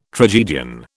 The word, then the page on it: tragedian: British English